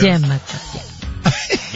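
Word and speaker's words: demagoguery: The pronunciation, like the emphasis is on the D. – So that's oh, it is. – Demagoguri.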